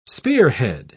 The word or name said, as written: spearhead